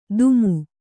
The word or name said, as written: ದುಮು